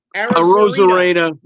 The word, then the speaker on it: Arozarena: Randy Arozarena.